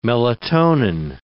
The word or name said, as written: melatonin